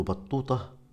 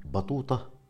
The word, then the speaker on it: بطوطة: ابن بطوطة وتلفظ أحيانا بطوطة